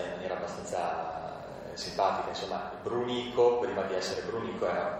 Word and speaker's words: Brunico: Comunque sì, Brunico è, sì, diciamo, grammaticalmente corretta perché l'accento va sulla penultima sillaba.